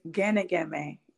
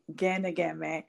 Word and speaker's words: Ginigeme: It's Ginigeme. Ginigeme.